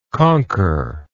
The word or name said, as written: conqueringly